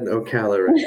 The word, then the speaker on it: Ocala: And I want to go back to, and I hope I'm saying this right, Ocala, Florida. Is that right? – Ocala. – Did I say that right? – Claps for that. I am impressed. – Okay. – Yes, you said it right.